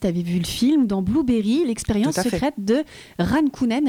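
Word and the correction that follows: Jan Kounen: Alors est-ce que ça se dit comme ça, Ran Kunen ? C'est Jan Kunen.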